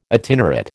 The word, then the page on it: itinerate: American English